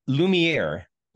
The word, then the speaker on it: Lumiere: It's Lumiere. – Lumiere.